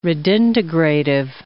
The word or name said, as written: redintegrative